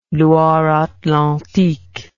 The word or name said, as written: Loire-Atlantique